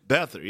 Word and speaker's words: Beathard: I heard that the last time that – how do you pronounce their quarterback's last name? Beathard? – Beathard, yeah. C.J. Beathard.